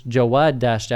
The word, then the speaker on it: Jawad Dashti: And it's Jawad Dashti. I say that in Arabic, by the way.